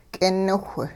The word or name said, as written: k'iniwh